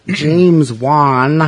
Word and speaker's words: James Wan: James Wan.